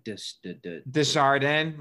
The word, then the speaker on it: Desjardins: It looks like it's spelled Henry, but I know that's not how you say it. – It was like Desjardins.